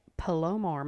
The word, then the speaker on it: Palomar: I drove up to, I might mispronounce this, Palomar Mountain, P-A-L-O-M-A-R, Palomar.